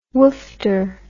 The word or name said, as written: woofter